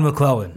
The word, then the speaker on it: McLellan: Todd McLellan.